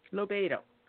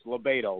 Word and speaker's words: LoBaido: Lobiedo. – That's Think of libido. Scott Lobiedo. – Exactly. Well, as an Italian, I say to my friends, it's Lobiedo, like tomato.